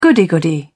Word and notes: goody goody: British English

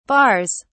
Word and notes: barres: British English

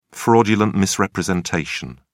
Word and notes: fraudulent misrepresentation: British English

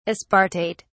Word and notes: aspartate: British English

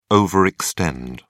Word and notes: overextend: British English

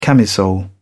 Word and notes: camisole: British English

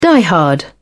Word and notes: diehard: British English

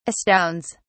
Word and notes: astounds: British English

